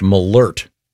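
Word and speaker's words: Malört: And this says it's pronounced Malort. – Malort. – There's a... was it an umlaut, the two dots over the O? This has a pronouncer saying Malort.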